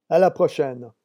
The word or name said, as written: a la prochaine